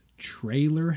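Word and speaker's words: Traylor: Trailer Howard? Not Taylor.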